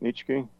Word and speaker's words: Nietzsche: A quote from Friedrich Nietzsche. Is that how you say it? – Nietzsche.